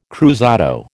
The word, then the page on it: cruzado: American English